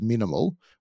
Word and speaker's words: MNML: So this particular business, when it was bought by AKA Brands, AKA Brands has their own brand called MNML, which I assume is pronounced minimal, which is very popular with NBA and NFL athletes in the US.